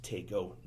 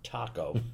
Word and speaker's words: Taco: So that's why it's pronounced Taco, not Taco, because also back in the 20s, burritos weren't that popular yet.